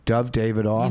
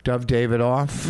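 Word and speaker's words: Dov Davidoff: It's Ted Alexandro went first, Dove Davidoff. – You know what? You said his name right, and I like that. – Dove Davidoff.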